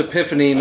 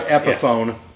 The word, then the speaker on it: epiphany: Yes, it's pronounced epiphany, not epiphone, yeah, by the way.